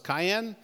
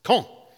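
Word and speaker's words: Caen: It, it spells C A E N. I thought it was Cayenne. It's not. It's Con. I was corrected.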